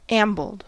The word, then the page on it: ambled: American English